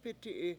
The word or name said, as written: p$Iti7i